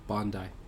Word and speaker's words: Bandai: Is it Bandai or Bandai? – Bandai. – Bandai Namco. Or Namco. – Yeah, it's Japanese, so Simba A is A.